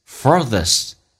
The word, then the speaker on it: furthest: 来看一下 furthest，那么这个需要重度卷舌 R，然后进行咬下舌头，叫 this furthest， T 不用发音，这叫略音，因为后面是以辅音开头，就变成 the furthest distance。